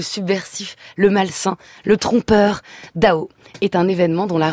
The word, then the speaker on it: DAU: Pas Dao Étienne, parce que ça se prononce comme ça, le chanteur, mais Do, D-A-U, prononcé Dao, ce projet artistique qui doit son nom à Lev Landau, physicien soviétique nobelisé en 1962.